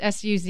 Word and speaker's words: Suze: I want to say Suze. It's Suze.